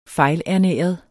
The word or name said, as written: fejlernæret